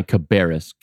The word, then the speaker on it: Cabarrus: And I'm, as if that's not enough, you know, on top of all that, in your county, Cabarrus County is how it's pronounced.